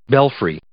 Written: belfry

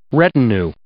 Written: retinue

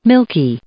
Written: milky

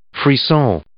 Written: frisson

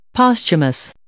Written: posthumous

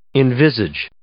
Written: envisage

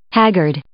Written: haggard

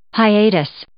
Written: hiatus

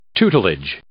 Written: tutelage

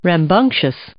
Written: rambunctious

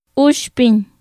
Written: uxpiñ

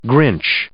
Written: grinch

